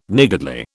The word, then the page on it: niggardly: British English